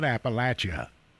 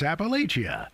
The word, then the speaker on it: Appalachia: And that is the correct pronunciation, Weed. It's not Appalachia. Or Appalachia. It's Appalachia.